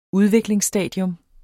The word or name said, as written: Udviklingsstadium